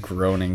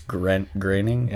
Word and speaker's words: Groening: Is that how you say his name? – Yeah. – I always said Groening because there's an O in it. – Yeah, it's O-E. – Yeah, it's Groening. – Yeah, I'm pretty sure I've heard him pronounce it on the show before.